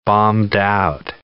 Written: bombed-out

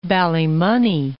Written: Ballymoney

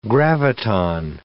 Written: graviton

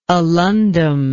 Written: alundum

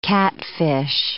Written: catfish

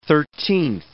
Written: thirteenth

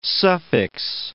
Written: suffix

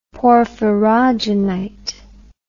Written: porphyrogenite

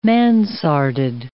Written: mansarded

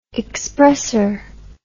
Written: expresser

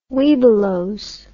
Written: Webelos

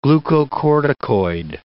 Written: glucocorticoid

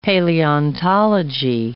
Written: paleontology

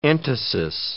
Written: entasis

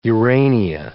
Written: Urania

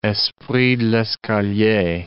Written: esprit de l'escalier

